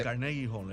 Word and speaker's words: Carnegie Hall: el Coney Hill, ¿verdad que sí? Así se pronuncia. El, eh, Carnegie Hall.